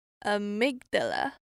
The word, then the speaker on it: amygdala: Amygdala，那我们重音是放在前面的二，所以是 amygdala。